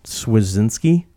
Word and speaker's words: Swierczynski: Dwayne Swierczynski, I think is how you say that name. – That's right.